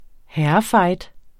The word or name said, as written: herrefight